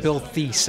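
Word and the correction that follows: Theiss: at the time. – Right. – And they corrected me. They said, no, it's Bill Tice. – I thought it was Thies. – Yeah, it's Tice.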